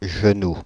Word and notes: genoux: France (Île-de-France); /ʒə.nu/

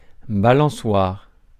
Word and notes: swing: American English

